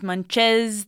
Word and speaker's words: Munch's: Munchie's Make Believe Band. – Not Munchie's, Munches. Thank you.